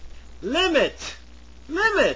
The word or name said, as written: limit